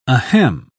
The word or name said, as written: ahem